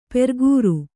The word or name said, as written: ಪ್ರಾಕಾರ